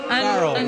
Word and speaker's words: Farrell: Am I pronouncing that right, sir? – Farrell.